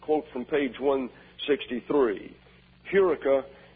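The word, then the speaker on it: Eureka (εὕρηκα): So that is H-E-U-R-E-K-A. But the first syllable is accented, is Eureka.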